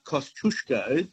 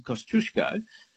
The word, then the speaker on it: Kosciuszko: There's one Australian place that is mispronounced every day of the week, and that is Kosciuszko, as in Mount Kosciuszko, the highest mountain in Australia, right? Mm-hmm. That's how the Poles would pronounce it, not Kosciuszko.